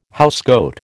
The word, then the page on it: housecoat: American English